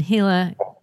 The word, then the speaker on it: Gila: 1910 Gila County Jail.